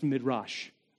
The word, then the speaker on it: Midrash: Okay, it's actually pronounced Midrash, I'm told.